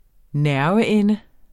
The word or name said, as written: nerveende